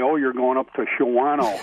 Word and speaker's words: Shawano: It's, and people put the A, they pronounce the A and they shouldn't. – Right. – Shawano. That's the Shawano instead of Shawano.